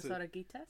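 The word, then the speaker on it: Dos Oruguitas: Das Oraguitas? – Yes.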